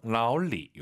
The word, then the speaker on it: 老李: 삼성, 삼성이니까 앞에가 이성입니다. 老, 그 다음 반삼성, 老李. 이렇게 발음하시면 됩니다.